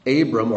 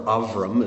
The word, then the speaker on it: Avram: "Now Sarai," Abram, or Avram is how it's truly pronounced, "Abram's wife, had borne him no children.